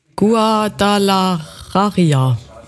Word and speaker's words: Guadalajara: Guadalajara.